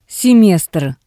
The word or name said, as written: semestr